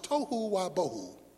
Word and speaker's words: tohu wa bohu: The Hebrew word for formless and void is pronounced tohu wa bohu.